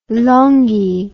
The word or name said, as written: Longhi